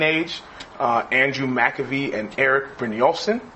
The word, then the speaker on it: Brynjolfsson: He has a TED talk and I listened to a bunch of his talks and I always heard them pronounce his name. So it's Brynjolfsson. I almost know how to spell it because I've seen his name so much now.